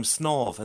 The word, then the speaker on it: Snave: They used to swim the cattle across there in the high tide, and that's it earned it the name Snov. And to this day it's spelt in English now, or some form of transliteration, S-N-A-V-E.